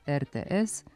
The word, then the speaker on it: RTS: Skraćenica za Radioteleviziju Srbije počinje sonantom R, te se otud najverovatnije ustalio dvoslovni izgovor RTS koji nije pogrešan.